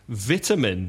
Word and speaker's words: vitamin: V-I-T-A-M-I-N. Vitamina in Spanish. So vitamin. – Oh, man. You mean vitamin. – Yeah, that's how the Americans say it. Vitamin. Stressing the first syllable. Say it again. – Vitamin. – Vitamin. – Stressing the syllable and changing the pronunciation from it to I. Vitamin.